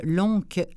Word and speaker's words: Loncke: C'est un Français, donc oui, on doit prononcer ça comme ça. Je vous l'appelle Lonck, L-O-N-C-K-E. Voilà, et c'était Pascal Bernard qui venait nous proposer ce livre-là.